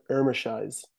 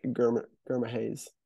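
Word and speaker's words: Germishuys: They say they don't really pronounce the G. It's like Irmishise. Dion. – Dion Irmishise. – Yeah, but there is a G there, so it's kind of like Gurmahase.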